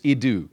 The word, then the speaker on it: idou: But the word is idou. It's I-D-O-U is the way we pronounce it, or the way we write it with our alphabet.